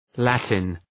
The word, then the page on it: Latin: ‘lætın